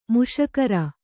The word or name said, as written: ಮೀಸೆಗಾರ